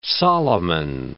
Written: Solomon

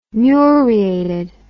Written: muriated

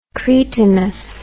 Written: cretinous

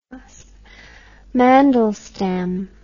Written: mandelstam

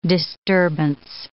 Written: disturbance

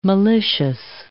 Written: malicious

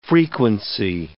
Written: frequency

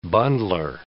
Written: bundler

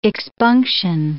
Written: expunction

